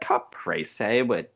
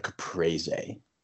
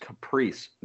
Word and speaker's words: caprese: called a caprese. And so you unwrap it. – Very important to specify, because it is not pronounced caprice, nor has it ever been.